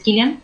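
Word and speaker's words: Cillian: de su nombre se escribe con C, pero es Killian, eh, que es un irlandés.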